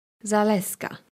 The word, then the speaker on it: Zaleska: E-W-A. – Family name? – Zaleska. – Zaleska. How do you spell that? – Z-A-L-E-S-K-A.